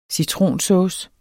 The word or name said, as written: citronsauce